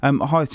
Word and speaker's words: Hythe: But Hythe Town in fifth, away to fourth place Phoenix Sports. That's the game of the weekend, surely. – Yeah, I think that's well pronounced. Good pronunciation of your H's there, in that one.